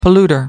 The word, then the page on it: polluter: American English